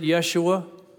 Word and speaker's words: Yeshua: The Hebrews would have pronounced it Yeshua.